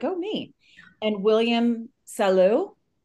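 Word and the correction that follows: Soleau: Yeah. – Oh, look at me. – Solo. William Solo. – Got it. I did not say that right. Okay. – Yeah. William Solo.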